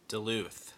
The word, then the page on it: Duluth: /dəˈluːθ/; də-LOOTH